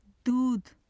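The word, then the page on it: dudh: Urdu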